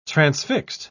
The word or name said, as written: transfixed